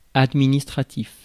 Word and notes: administratif: France; [ad.mi.nis.tʁa.tif]

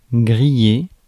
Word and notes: grillé: France; /ɡʁi.je/

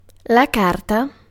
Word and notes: document: American English; /ˈdɑkjʊmənt/

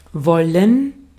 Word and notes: wollen: /ˈvɔ.lən/

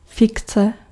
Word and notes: invention: /ɪnˈvɛnʃən/

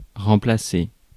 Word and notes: remplacer: France; /ʁɑ̃.pla.se/

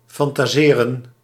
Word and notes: fantaseren: /ˌfɑntaˈzeːrə(n)/

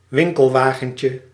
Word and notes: chariot: /ʃa.ʁjɔ/; /ʃa.ʁjo/; France

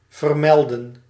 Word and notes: mention: /ˈmɛnʃən/

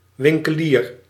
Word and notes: winkelier: /ʋɪn.kə.li:ɾ/

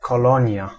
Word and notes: colonie: France; [ko.lo.ni]

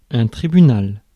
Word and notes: tribunal: /tʁi.by.nal/; France (Paris)

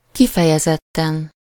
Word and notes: kifejezetten: /ˈkifɛjɛzɛtːɛn/

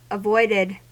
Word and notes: avoided: American English

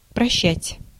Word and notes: прощать: /prɐˈɕːatʲ/

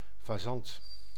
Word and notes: faisan: France; /fə.zɑ̃/

